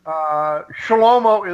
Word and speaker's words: Shlomo: Shlomo is Solomon, okay? But I use the Hebrew pronunciation.